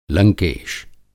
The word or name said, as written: लंकेश